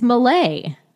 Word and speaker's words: melee: Melee? – Melee? It's Malay.